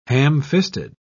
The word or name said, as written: ham-fisted